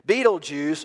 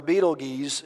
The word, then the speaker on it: Betelgeuse: Betelgeuse, or Betelgeuse, two ways to pronounce that, is a star in our galaxy.